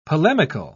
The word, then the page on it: polemical: British English